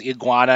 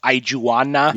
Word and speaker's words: iguana: We also learn here that Peggy pronounces iguana as Ijuana.